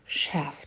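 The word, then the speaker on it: chef: Смотрите, шеф-повар, шеф, читается не через звук ч. Прям обратите внимание, там в начале звук ш. Шеф. Вот как у нас шеф-повар, и в английском тоже шеф.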